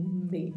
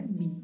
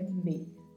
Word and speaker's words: me: E o me é falado assim mesmo, me, não é mi, é me, acompanhar-me.